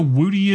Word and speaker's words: Wootie: Do you know what a Wooty is, Paul? – How do you say it? – Wooty. W-O-O-T-I-E.